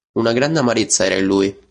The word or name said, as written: lui